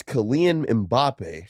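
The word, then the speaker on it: Kylian Mbappé: France's Kylian Mbappe. – Kylian Mbappe. – That's his name. That's how you say it. Kylian? – Kylian. – Kylian. – Kylian Mbappe. Yep.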